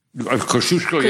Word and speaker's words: Kosciuszko: Kosciuszko, yes, and there's a Pulaski, too.